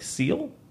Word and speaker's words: Ciele: It's C-I-E-L-E. I believe it's pronounced like Seal.